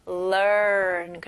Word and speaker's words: learn: 여기서는 중간에 R 발음, 그리고 앞에 L 발음도 있죠. 그래서 learn. 그래서 R까지 가실 때는 혀가 뒤로 가는 느낌이 나야 돼요.